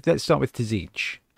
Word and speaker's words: Tzeentch: So all right, let's start with Tzeentch. Don't know why I added an extra syllable in there.